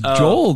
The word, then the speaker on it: Joel: And people can't pronounce that for some reason. Joel? Is it Joel? I'm like, what the fuck? It's Joel, dude. You serious? That's how you spell Joel.